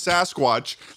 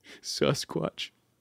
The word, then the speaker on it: Sasquatch: You cannot have a normal conversation and then also just say Sasquatch. You cannot pronounce Sasquatch like Sasquatch and expect that we're not going to catch you on that. Say it right. – Sasquatch.